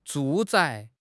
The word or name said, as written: 住宅